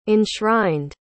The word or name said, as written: enshrined